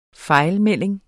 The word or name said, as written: fejlmelding